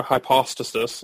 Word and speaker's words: hypostasis: In other words, so we talk about a hypostasis, a hypostasis, I think the correct way of pronouncing that, is just the Greek word for person or subsistence or individual.